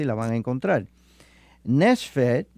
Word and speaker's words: nephesh: La palabra hebrea que en esta escritura se emplea por alma es nefesh, que se escribe N-E-P-H-E-S-H, que es la palabra hebrea.